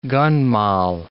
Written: gun moll

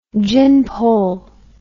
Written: gin pole

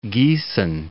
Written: Giessen

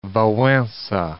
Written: Walesa

